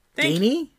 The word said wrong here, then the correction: Dennehy: Diney? I don't know how to pronounce that. – Ned Dennehy? – Dennehy. Okay, yeah.